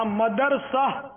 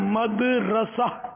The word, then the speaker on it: مدرسہ: ہاں مدرسہ یہ غلط العوام ہے، اصل اس کا تلفظ ہے مدرسہ، میم دال زبر مد، را زبر را، را زبر را، اور سین آ زبر سہ، اردو کے حساب سے ہجے اس کی یہ بنے گی.